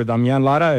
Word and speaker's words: Damian Lara: So once again, Damian Lara, or Damian Lara, if you're angry with me, you can yell that.